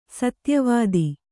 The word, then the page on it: ಸತ್ಯವತಿ: satyavati; kannada